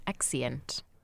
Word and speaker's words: exeunt: Are you saying exeunt? – I've been saying it wrong this whole time? – It is. It's actually pronounced exeunt.